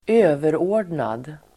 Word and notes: överordnad: [²'ö:verå:r_dnad]

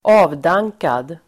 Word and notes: avdankad: [²'a:vdang:kad]

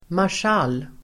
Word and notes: marschall: [marsj'al:]

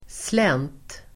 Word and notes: slänt: [slen:t]

